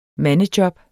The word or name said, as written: mandejob